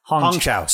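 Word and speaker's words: Hong Chau: I think that he could have showed up at the very beginning, had that very initial conversation with Ho Chow's character Liz at the very beginning. – And Ho Chow? – Hong Chow. Sorry.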